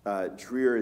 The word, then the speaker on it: Dreher: His last name is pronounced Dreher, but it's really spelled D-R-E-H-E-R.